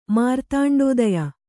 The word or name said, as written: ಮಾರ್ತಾಂಡೋದಯ